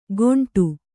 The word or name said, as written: ಗೊಂಡು